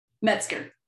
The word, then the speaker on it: Metzger: Metzger.